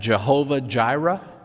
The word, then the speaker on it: Jehovah Jireh: That Yahweh will provide, Yahweh Yireh, you've probably heard the Germanized version of that, Jehovah Jireh. That's because in German the J is pronounced like a Y.